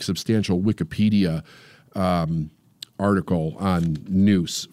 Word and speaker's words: nous: And one of the great things to know about this was that Pericles practiced something called nous, N-O-U-S, N-O-U-S-E, I believe it is. N-O-U-S, but it's pronounced noose, like N-O-O-S-E.